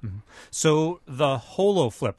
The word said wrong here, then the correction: HoloFlop: or HoloFlip, how do you say it? – We've come up with the name HoloFlop.